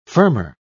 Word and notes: firmer: British English

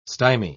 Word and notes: stymy: British English

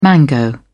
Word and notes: mango: British English

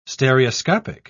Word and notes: stereoscopic: British English

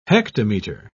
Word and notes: hectometre: British English

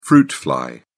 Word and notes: fruit fly: British English

